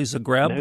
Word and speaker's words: Zagreb: The book is The Lady from Zagreb. Am I pronouncing that correctly, Zagreb? – No, that's correct.